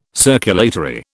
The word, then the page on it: circulatory: British English